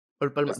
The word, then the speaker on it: Olpalma: Ol Palma.